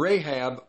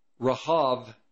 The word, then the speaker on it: Rahab: And Rahab, or it's Rahav in the in the Hebrew, R A H A B, but it's the B is silent. It's Rahav.